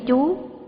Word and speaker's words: chủ: Theo ông Hoàng Xuân Hãn thì ghi mấy chú, còn ở đây là mấy chủ, vì chữ nôm nguyên là chữ chủ, có thể đọc là chú, nhưng đây giữ nguyên là chủ.